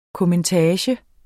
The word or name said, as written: kommentage